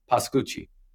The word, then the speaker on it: Pascucci: The Italian sense is Pascucci.